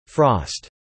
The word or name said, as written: frost